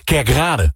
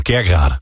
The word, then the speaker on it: Kerkrade: Ik ben zelf Limburger en ik erger heel erg vaak als mensen bijvoorbeeld zeggen... Kerkrade. Het is gewoon Kerkrade. – Kerkrade? – Kerkrade. Je moet de klemtoon altijd goed leggen.